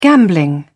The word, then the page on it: gambling: British English